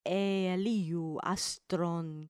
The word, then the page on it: eeliu astron: grec